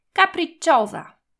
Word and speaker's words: capricciosa: Capricciosa, pisana przez pojedyncze P, podwójne C i pojedyncze S, które wymawiamy jako Z.